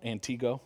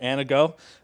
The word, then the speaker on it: Antigo: And so if you think of where you're at Antigo, am I pronouncing it right? Not Antigo, right? Antigo.